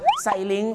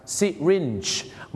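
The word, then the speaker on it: syringe: ไซลิงไซลิงหรือซาลิงซาลิงไซลิงนี่นะครับเขาไม่ได้ออกเสียงว่าไซลิงนะครับไม่งั้นเดี๋ยวฝรั่งเขาจะนึกว่าคุณจะเอาเขาขึ้นสลิงห้อยโหนในโรงพยาบาลสิครับที่จริงแล้วนะครับคำว่าเข็มฉีดยาเขาออกเสียงว่าซิรินจ์นั่นเองครับซิรินจ์เ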